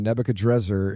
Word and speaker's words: Nebuchadrezzar: Yeah, that's, there, that's it's a there are a couple of ways to pronounce his name, and Nebuchadrezzar is hmm. – probably the more accurate one. But of course, Nebuchadnezzar is so popular, it's hard to run against it.